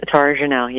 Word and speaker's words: Tara-Jenelle: Tara-Jenelle, yes, Jenelle. Like Marianne.